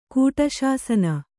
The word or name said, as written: ಕೂಟಶಾಸನ